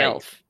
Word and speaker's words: Guelph: Guelph.